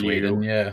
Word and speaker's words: Whedon: And I should probably mention that this film was written by Joss Whedon, or Whedon. I'll never know how you pronounce his name. – Yeah, so I think it's Whedon.